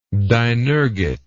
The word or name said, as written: dinergate